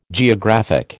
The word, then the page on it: geographic: American English